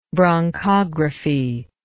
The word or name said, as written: bronchographic